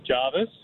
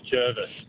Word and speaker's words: Jervis: As your producer corrected me, I pronounced it Jarvis. He told me the people that grow up there, they called it Jervis.